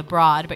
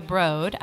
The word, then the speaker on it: Broad: It's spelled like the Broad, but you're supposed to say the Broad.